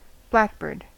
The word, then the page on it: blackbird: American English